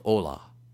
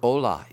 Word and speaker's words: ollamh: Allen, in Judah's Scepter and Joseph's Birthright, the word ola is Hebrew for revealer of hidden knowledge or prophet. That word ola is spelled O-L-L-A-M-H.